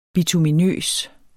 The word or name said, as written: bituminøs